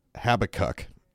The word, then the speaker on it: Habakkuk: And so she pronounces Habakkuk as Habakkuk. And I totally think that's probably the right pronunciation.